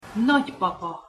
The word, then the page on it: nagypapa: Hungarian